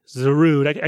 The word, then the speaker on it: Zarud: I think sort of the big thing we should focus on here is the Dada Zerude, Zarud. I keep wanting to say it at three syllables. It is only two.